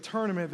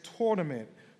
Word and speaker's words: tournament: They say coffee instead of coffee, or they will say, instead of tournament, they will say tournament, like it's tournament, not tournament.